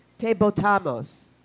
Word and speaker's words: te botamos: Anyway, they got Ricky Rosello to resign, and they chanted, "Ricky, te botamos." Ricky, we threw you out. Where's Anna? Did I pronounce that right? Ricky, te botamos. Is that right? Te botamos? – Yeah, okay.